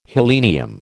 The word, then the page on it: helenium: American English